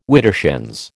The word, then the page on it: widdershins: American English